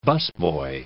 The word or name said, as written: busboy